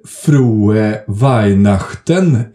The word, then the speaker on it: frohe Weihnachten: Det är väl på sin plats att man snarare säger frohe Weihnachten än någonting annat i den här podden, va? Var det korrekt uttal, Charlie? – Ja, mycket bra.